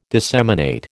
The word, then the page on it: disseminate: American English